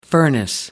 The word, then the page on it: furnace: American English